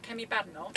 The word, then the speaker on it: Kemi Badenoch: How do I say his name? – Kemi Badenoch. – Kemi Badenoch, okay.